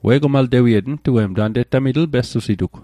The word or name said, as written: maltewiet/mal'tewiet